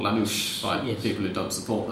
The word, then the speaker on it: Lanús: Lanús. – There we go. No sh sound, and the stress goes on the last syllable, and the A is short, la, not la.